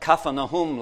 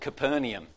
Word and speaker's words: Capernaum: And she said, oh, you pronounce it Capernaum. Capernaum. Okay. So Kaffa is the word for village and Nahum is how we pronounce Nahum, okay? So it means the village of Nahum, Capernaum. That's what Capernaum means.